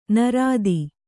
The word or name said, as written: ನೈಋತ